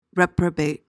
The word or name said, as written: reprobate